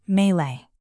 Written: melee

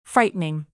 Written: frightening

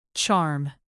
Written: charm

